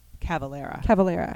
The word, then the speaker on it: Cavalera: Born in 1984 by brothers Max and Igor Calavera. – That's it. Yes. – Calavera. – My brother. – Oh no! Sorry. Cavalera. I said it wrong.